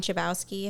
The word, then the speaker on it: Chbosky: Chbosky. I think I'm saying that right. – Yeah, sounds right.